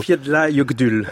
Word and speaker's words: Eyjafjallajökull: Eyjafjallajökull.